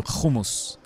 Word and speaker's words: hummus: By the way, you're referring to hummus is the pronunciation.